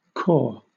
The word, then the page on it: cor: British English